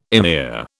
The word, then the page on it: emir: British English